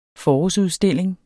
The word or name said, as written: forårsudstilling